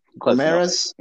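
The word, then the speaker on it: Guimarães: Guimaraes? – Yeah.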